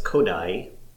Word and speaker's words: Kodály: but Kodaly, is that how you pronounce it? – It's a Hungarian word, so... – Excellent. – So it's Kodály.